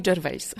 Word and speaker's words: Gervais: Ricky Gervais, dobrze mówię? – On sam mówi Gervais.